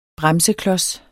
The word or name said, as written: bremseklods